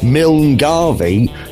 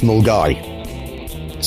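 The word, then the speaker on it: Milngavie: But any self-respecting Scotsman, anyone who spent any time in Glasgow, will know that Milne Garvey is pronounced Mulgai.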